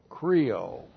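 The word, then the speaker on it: chriō: C-H-R-I long O. Krio.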